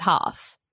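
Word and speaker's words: Hass: And you pronounced Haas avocados Haas. Michelle says it's Haas. – Yeah, Haas with a H. Yes. – Where did you get ass with a H from? It's Haas. It's H-A-S-S.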